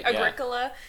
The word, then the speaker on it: Agricola: Are you talking about Agricola? – I am. – How do you say it? – No, you're right. – Agricola.